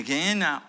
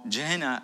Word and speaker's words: Gehenna: Now, having said that, the Gehenna, or Gehenna, according to other way to pronounce it,